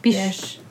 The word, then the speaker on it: Bysshe: But it's spelled B-Y-S-S-H-E, but Bish.